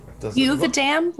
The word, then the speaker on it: Yuvuadam: Yuvuadam, like Yuvuadam. – But pronounced like you have a dam.